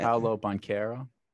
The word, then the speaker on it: Banchero: B-A-N-C-H? – Yeah. – Paolo Banchero. – So I was right. Paolo Banchero.